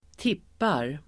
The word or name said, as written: tippar